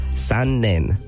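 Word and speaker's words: san-nen (残念): This one means, like, unfortunately, too bad, or what a shame, and it's pronounced san-nen. San-nen. It's broken up pretty much into two words, san and nen. Z-A-N and then N-E-N. San-nen.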